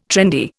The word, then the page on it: trendy: Indian